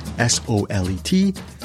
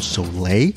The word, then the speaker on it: Solet: I actually thought the way to pronounce his name was Paul Soleil, and I was lucky because the publicist who was handling the press for this movie, the movie is called Clean, actually pronounced his name Paul Soleil, and then he corrected her. He said it's Paul Solet.